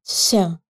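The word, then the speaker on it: się: Она же есть и в польском глаголе, произносится как «ся».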